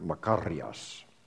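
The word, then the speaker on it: makarios (μακάριος): And the Greek word that's translated blessed is the word makarios, or for you Greek scholars, you actually roll your R's in Greek, makarios.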